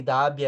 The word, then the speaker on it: Dhabi: Fouzia, Fouzia se escreve, se escreve F-A-O-U-Z-I-A. E joga aí no YouTube, Fouzia Abu Dhabi. Abu é A-B-U, e aí Dabi é D-H-A-B-I.